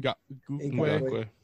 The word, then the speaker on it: Ngakoue: I think especially with how how do you pronounce the Yannick what and Ngakoue Ngakoue